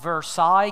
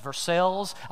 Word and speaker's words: Versailles: It's also not Versailles, Kentucky. It is Versailles. I know we're backward, but it doesn't matter.